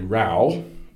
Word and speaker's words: row: And he says, in the living room of my AO set, Robert De Niro has a row, rhymes with cow.